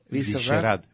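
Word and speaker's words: Vyšehrad: Visherad.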